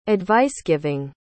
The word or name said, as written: advice-giving